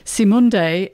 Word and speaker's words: Cymande: Simunde, it's spelt C-Y-M-A-N-D-E.